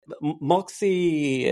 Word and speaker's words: MUXI: But yeah, Moxie, it's spelled MUXI.